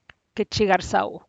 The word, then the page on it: ketxi garsau: Teotitlán del Valle Zapotec